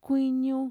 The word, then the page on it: kuiñu: Mixtec